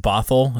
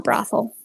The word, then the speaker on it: Bothell: Right there in is it Bothell? Is that how you say it? – Yes, not brothel. Bothell. – Yeah, that's great. There's no R in there.